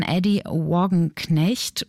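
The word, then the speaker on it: Addie Wagenknecht: Und das ist ein Kunstwerk, das von der Künstlerin Eddie Wagenknecht, wie würde man diesen Namen englisch aussprechen? Adi Wagenknecht, sage ich jetzt mal hier auf Deutsch.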